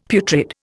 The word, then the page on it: putrid: Indian